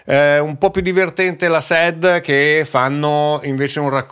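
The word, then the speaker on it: La Sad: Partiamo da eh, formazioni come il trio dei La Sed, perché si pronuncia così, Bunker 44, eh, Maninni, i Santi francesi, Clara.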